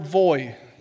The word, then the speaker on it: voi: And he said it's the word voi, V-O-I. I wrote V-O-Y so I'd say it right. V-O-I is voi.